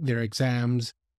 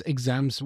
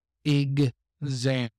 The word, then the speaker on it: exam: A lot of people say exam, but it's not that, the word does not have that stress. It's exam, and it sounds like a g and a z, ig-zam.